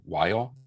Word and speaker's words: Weil: Weil.